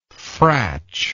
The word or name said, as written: fratch